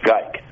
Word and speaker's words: Gyke: And you're right, it did say G-Y-K-E. And in the cipher before it was solved, it said G-Y-K-E in a portion of it, and that pronounced Geik. And he did write under the name Dick Geik when he was in San Francisco during the Zodiac crimes.